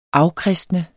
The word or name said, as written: afkristne